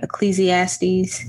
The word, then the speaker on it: Ecclesiastes: And this was quoted, I hope I'm saying this right, Ecclesiastes. Is that how you say it? – Ooh, girl, you a heathen. You need to take your behind back to church. – How do you say it? – No, you said it right.